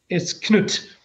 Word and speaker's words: Cnut: Because in Danish, it's spelled with a K, K-N-U-D, and it's just pronounced Cnut. But it's Cnut.